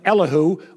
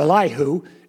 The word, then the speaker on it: Elihu: Then the young man, by the name of Elihu, or Elihu, Elihu's correct, because that's what I call him. No, both pronunciations are acceptable.